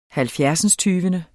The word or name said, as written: halvfjerdsindstyvende